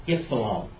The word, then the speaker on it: ypsilon: And by the way, this is the way you pronounce this, ypsilon. See that word, delta, ypsilon, nu? It's not in Black Davis's Great Grammar, many of the grammars, it will pronounce it oo, ypsilon. But it's not oo, it is it, like a y.